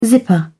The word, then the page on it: zipper: British English